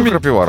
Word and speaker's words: крапива: Да знаешь, что крапива разная бывает? – Крапива или крапива? – Ну, она же два ударения.